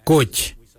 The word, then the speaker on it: Goodj: Garli is spelled G-I-I-L-Y-I, garli. For the traditional stone axe we say goj, goj. Goj is spelled G-O-O-D-J, Koich.